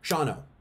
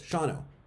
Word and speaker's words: Shawano: How many syllables is Shawano? It's a Native American term, Shawano. Shawano. Two syllables, Shawano.